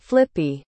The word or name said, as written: flippy